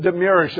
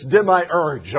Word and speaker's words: demiurge: Then I also talked last week about, I called it demiurge, and that's a wrong pronunciation. It's demiurge. Okay, say it with me.